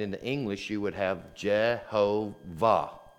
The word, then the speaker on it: Jehovah: In fact, you may find in some older Bibles, you may find the word Jehovah. Perhaps you have heard that. And so that is actually a German assigning vowel sounds, and instead of doing it like this, the Y has become kind of a hard sound, a J. And then instead of an A, you have an E here,